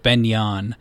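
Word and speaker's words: Ben Eoin: There's a ski hill called Ben Yon, and it's spelt in a way that you would never imagine. It's like Beneion, and it is outside of Sydney, maybe like a half hour drive or so.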